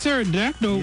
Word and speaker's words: Pterodactyl: Big Pterodactyl. – Yeah, that's my new name. – Okay. – The P is silent.